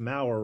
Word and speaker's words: Maurer: It's Maurer, rhymes with flower.